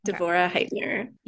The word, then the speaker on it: Devorah Heitner: Devorah Heitner.